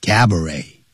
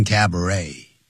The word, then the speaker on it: cabaret: 什么哪两种东西结合在一起？Dance hall 也就是舞厅，以及 and cabaret，以及有表演的餐厅啊，有节目表演的餐厅结合在一起。你可以跳舞，又可以用餐，同时也可以看到舞台上的表演。叫 cabaret，那也是法语，所以那个 T 是不发音的 cabaret，也有人念成 cabaret。